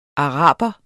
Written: araber

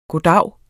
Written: goddag

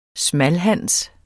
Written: smalhans

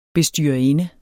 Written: bestyrerinde

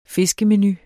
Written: fiskemenu

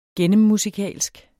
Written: gennemmusikalsk

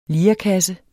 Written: LIREKASSER

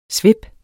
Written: svip

